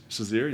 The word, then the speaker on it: Shazier: How do you say his last name? Ryan Shazier? – Shazier, yeah.